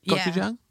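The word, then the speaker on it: Gochujang (고추장): Gochujang, is it? Is that how you pronounce it? Gochujang? – Yeah.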